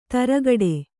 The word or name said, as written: ತುರುಕುಡಿ